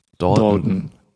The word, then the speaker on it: Dalton: Heißt er Timothy Dalton oder Dalton? Dalton, oder? – Ich weiß ihn noch nicht. Ich habe das immer so ausgesprochen wie die Lucky Luke-Brüder. – Dalton. Ja. Genau.